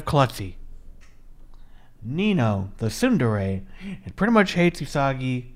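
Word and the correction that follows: Uesugi: It's Usugi. I've been saying the name wrong the entire time.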